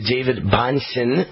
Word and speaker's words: Bonson: We're talking to David Bonson, rhymes with Johnson.